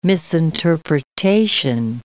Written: misinterpretation

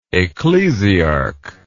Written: ecclesiarch

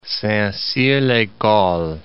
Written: Saint-Cyr-l'ecole

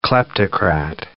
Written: kleptocrat

